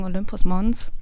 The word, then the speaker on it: Olympus Mons: Ich würde sagen, Olympus Mons, Mons.